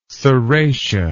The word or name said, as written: serratia